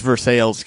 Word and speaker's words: Versailles: But then, yeah, Popper posted an aerial view of Rutherford's house with the caption, "This is the Rutherford home in Versailles, Kentucky." It's Versailles, Kentucky, I should say, because that's how they pronounce it there.